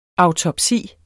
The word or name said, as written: AUTOPSIER